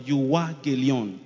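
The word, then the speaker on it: euangelion (εὐαγγέλιον): When you hear the word gospel, the gospel is gotten from a word, euangelion, a Greek word called euangelion.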